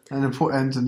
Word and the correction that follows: Pointe: Das heißt Pointe, du, du Halbaffe.